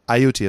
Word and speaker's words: Ayutthaya: Now this final battle is commemorated by the Thais on a pretty regular basis in a place, a city called Ayutthaya. Yeah, it took me a couple of times to learn how to pronounce that, too. Ayutthaya is the name of the city.